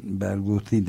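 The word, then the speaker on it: Barghouti: Bergusi değil mi? – TH diye yazılıyor. Bergusi. – Ha, diye okunuyor. Mervan Berguti demek lazım sanıyorum. Ya da Berguti de diyebiliriz.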